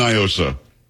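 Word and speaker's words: NIOSA: Do not, because it's definitely Nyosa. It is not a Spanish word. What does Nyosa mean in Spanish? Nothing. – So, I don't know. – Well, that's the whole argument. They try to pronounce it like it's Spanish.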